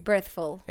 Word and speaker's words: Birthful: The Birthful Podcast. Birthful. Eh, pronúncialo tú bien, porque. Birthful. Exacto. Ok.